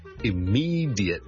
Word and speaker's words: immediate: Hi, 曾故事又见。今日同大家讲一个好常用嘅一个字嘅英文发音，就系咧 immediate, I M M E D I A T E，立刻立即。咁啊，有个听众问少少，die 咧应该点样读得好啲？正常咧，我哋如果系好快咁读咧，我哋可能会读成 die 嘅啫，die die die，好似 J I T 咁嚟读。